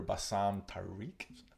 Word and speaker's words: Bassam Tariq: Director Bassam Tariq. Am I saying it right? – I believe so.